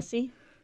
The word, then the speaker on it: Jussie: I never said it right. – Jussie? – Smollett? – Yeah.